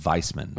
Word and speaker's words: Weissman: I think it actually is it's the pronounced with a V, so it's Weissman.